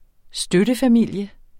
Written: støttefamilie

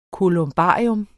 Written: kolumbarium

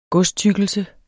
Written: godstykkelse

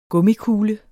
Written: gummikugle

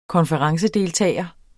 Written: konferencedeltager

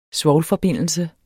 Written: svovlforbindelse

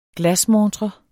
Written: glasmontre

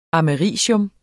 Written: americium

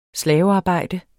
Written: slavearbejde

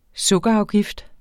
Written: sukkerafgift

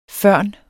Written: førn

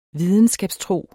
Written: videnskabstro

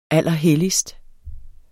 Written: allerhelligst

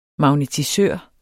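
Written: MAGNETISØR

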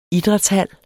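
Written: IDRÆTSHAL

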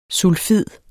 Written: sulfid

